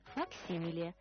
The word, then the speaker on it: факсимиле: А я еще раз об ударении. Факсимильный, факсимиле.